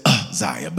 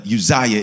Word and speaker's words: Uzziah: Donald Lawrence says Uzziah, but Uzziah is the pronunciation.